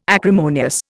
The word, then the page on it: acrimonious: Indian